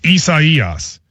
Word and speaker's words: Isaias: People are saying, you placed the syllable on the emphasis on the wrong syllable. It goes on the third syllable. So it's Isaias.